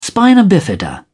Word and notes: spina bifida: British English

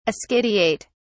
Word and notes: ascidiate: British English

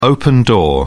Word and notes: open door: British English

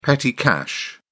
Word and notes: petty cash: British English

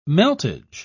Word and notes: meltage: British English

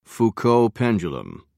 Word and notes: foucault pendulum: British English

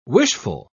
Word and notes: wishful: British English

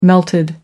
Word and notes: melted: American English

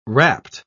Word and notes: wrapt: British English

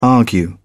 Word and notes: argue: British English

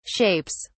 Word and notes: shapes: British English

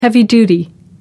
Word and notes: heavy-duty: American English

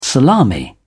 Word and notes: salami: British English

